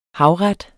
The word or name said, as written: havret